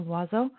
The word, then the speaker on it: Loiseau: So Natalie Loiseau, is that how you would pronounce it? I think so, Loiseau.